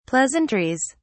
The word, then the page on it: pleasantries: British English